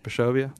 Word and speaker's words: Bischofia: She said she had a 60-foot Bischofia tree. – Correct. – Am I saying that right? – Yes, Bischofia.